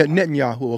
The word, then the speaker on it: Netanyahu: And I think the reason why is, you know, because certain statements are made like King Trump, you know, Yahoo, you know, it's Bitten Yahoo, whatever you however you pronounce his name. It's Netanyahu. – Almost yeah, Netanyahu. Okay, thanks for correcting me.